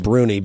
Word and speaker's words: Bruni: I was reading something that was a column by Frank, I'm going to pronounce his name Bruni, B-R-U-N-I.